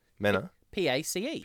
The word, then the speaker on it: Menapace: No, you've murdered it. Butchered it. – Absolutely. – Menapace. Menapace. – Mena. – P-A-C-E. – P-A-C-E. Pace. – Menapace, yeah.